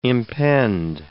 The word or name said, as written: impend